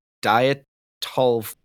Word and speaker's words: Dyatlov: So I believe the correct pronunciation is the Dyatlov Pass incident, but it's also named the Devil's Pass, which is a movie that was made after the events that took place.